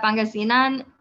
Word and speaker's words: Pangasinan: Pangasinan and Siquijor.